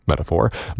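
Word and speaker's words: Meta4: Felix Mendelssohn's Octet, which doubles a string quartet, and we heard the Swiss Gringotts Quartet, and the Finnish Metaphor, which is pronounced metaphor, but is spelled meta and then the number four.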